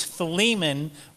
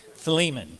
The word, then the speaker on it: Philemon: It's Philemon, which I've pronounced wrong all my life too. Philemon. Let's just say it together. Maybe it'll help me. Philemon. Okay.